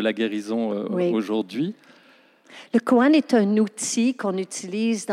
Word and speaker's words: koan: Alors, parle-nous du principe du koan. Je ne sais pas si je le prononce bien, mais... – Oui, euh, très bien.